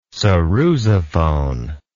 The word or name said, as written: sarrusophone